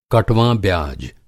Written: कटवाँ ब्याज़

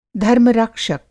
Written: धर्म-रक्षक

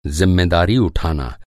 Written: ज़िम्मेदारी

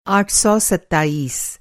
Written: आठ सौ सताईस

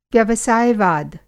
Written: व्यवसाय-वाद

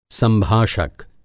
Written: संभाषक

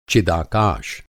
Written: चिदाकाश

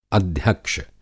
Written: अध्यक्ष

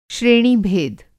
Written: श्रेणीभेद

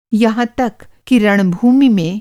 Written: रण-भूमि

